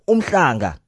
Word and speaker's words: Umhlanga: It's not Umhlanga. It's Umhlanga. That's what it is. Not Umhlanga. Umhlanga, mm-mm. Umhlanga, yes. That's how it's supposed to be. pronounced.